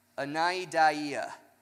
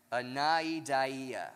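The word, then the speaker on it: Anaideia (ἀναίδεια): Anaideia. I looked it up phonetically and I practiced it, but I didn't say it as confidently and smoothly as I wanted to, but that is how you say it.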